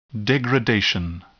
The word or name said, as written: degradation